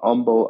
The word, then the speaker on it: Humble: One of them is in Humble, Texas. For those non-Texans, you might pronounce it Humble because that's how it's spelled. but in Texas, it's pronounced humble.